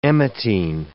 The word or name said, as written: emetine